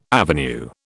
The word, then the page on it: avenue: British English